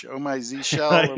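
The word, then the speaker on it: Oh My Zsh: and there's like you know, Z Shell and Oh My Z. How do you pronounce it? Oh My Z, Oh My Z Shell.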